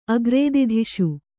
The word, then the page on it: ಅಗ್ರೇದಿಧಿಷು: agrēdidhiṣu